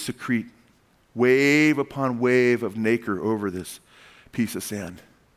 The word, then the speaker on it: nacre: It secretes an element called nacre, N-A-K-E-R, N-A-C-R-E, I think, actually. That's how you spell it. Nacre, though, it's pronounced nacre,